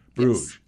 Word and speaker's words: Bruges: I hope I'm pronouncing it right. Is it Bruges? – Mm-hmm. – Bruges. – Yes, Bruges.